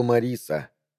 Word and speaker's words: Марис: Также я увидел Мариса, произносится как Марис Гарденера, местную, можно сказать, знаменитость для тех, кто, подобно мне, имеет слегка чернушное чувство юмора.